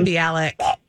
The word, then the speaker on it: Mayim Bialik: And then Call Me Cat on Fox with Mayim Bialik. I can never say her name. – Mayim Bialik.